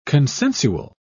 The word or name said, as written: consensual